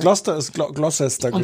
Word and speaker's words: Gloucester: Spricht man Gloucestershire Gloucester aus? Ja, ja. ne? Habe ich immer gefragt, warum da so viele Buchstaben hinten dran sind, wenn man die dann gar nicht hört.